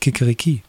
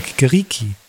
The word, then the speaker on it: Kikeriki: finde ich ein spannendes Thema, weil auf Deutsch sind ja schon die Hähne äh, das Kikeriki das hat ja schon die falsche Betonung eigentlich. Ein Hahn sagt ja nicht Kikeriki, sagt ja Kikeriki.